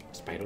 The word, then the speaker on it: Spyro: Spyro, lo he dicho bien.